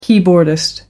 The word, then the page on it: keyboardist: American English